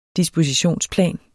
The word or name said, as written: dispositionsplan